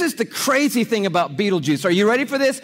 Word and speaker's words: Betelgeuse: It's called Betelgeuse or Betelgeuse. You can pick your pronunciation. I'm obviously going with Betelgeuse, And Betelgeuse is incredible.